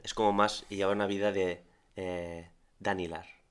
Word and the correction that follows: Dalinar: ¿no? Dalinar. – Dalinar, sí. Y lo he dicho mal.